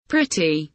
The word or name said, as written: pretty